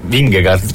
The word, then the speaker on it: Vingegaard: Vingegaard, si pronuncia così? Sì, c'è.